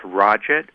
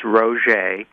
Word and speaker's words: Roget: And she told me, she said, when this book gets published, you've got to tell those Americans how to pronounce the name. Because in America, the name was first pronounced Roget. – I suppose. – When the book became a big hit in the 1920s due to the crossword puzzle craze. So the name is Roget, and it means little red man, and he had both sides of his family were French.